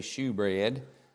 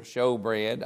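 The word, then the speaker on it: shewbread: And I know that we read eschew, E-S-H-E-W. Lots of times when we read S-H-E-W when it's in the New Testament, some people read it shoe, and that's fine. So we could say shoe bread here, showbread. I've heard it there, and I've heard people pronounce S-H-E-W as show.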